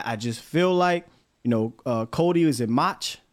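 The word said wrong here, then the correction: Mauch: I always say his name wrong. – Yeah, no, Cody Mock.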